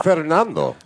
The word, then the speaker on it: Fernando: I hear you guys have a very high following of people named Fernando. So you said it wrong. – Tune in next week. – Fernando. – There you go. – You rolled that R in there.